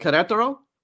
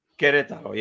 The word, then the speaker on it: Querétaro: Querétaro. Is that the way you pronounce it? Right? – Querétaro. Querétaro.